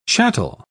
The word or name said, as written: chattel